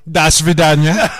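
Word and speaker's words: Das Vidania (До свидания): And Das Vidania. – I knew that was coming. – Did I say it correctly? Did I say it with the good? – You did. It was very good.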